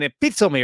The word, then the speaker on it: epitome: So it's an interesting word, an epitome. But what's even more interesting is how this word is spelled. It's spelled E-P-I-T-O-M-E. Unbelievable, right? It's not really spelled as it sounds.